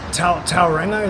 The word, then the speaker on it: Tauranga: I've been to the Mount, Tauranga, is that how you say it? – Yeah.